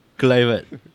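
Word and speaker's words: Kluivert: Why do you pronounce it Kulivert? – Clivert. Sorry, Clivert, yeah.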